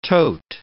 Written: tote

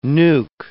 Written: nuke

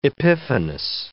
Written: epiphanous

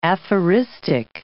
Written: aphoristic

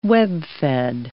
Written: webfed

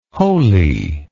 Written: holi

